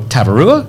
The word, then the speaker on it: Tavarua: It's something Ampachua. And she's trying to pronounce Tavarua. And I go, Tavarua? And she goes, yeah, that's it.